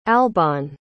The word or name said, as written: albane